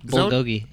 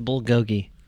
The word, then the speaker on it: bulgogi: Bulgogi, yeah. – Well, how do you say it? – Bulgogi. – I heard it was bulgogi. – That's Korean, right? – Is it? It's not goji. It's gogi, right? – Mm-hmm.